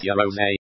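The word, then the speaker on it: Yaroze: The word Yarrows, pronounced Yarose, is a Japanese idiom meaning let's do it together, and that's the key to the system.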